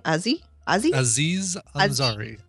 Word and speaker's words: Aziz Ansari: Aziz Ansari.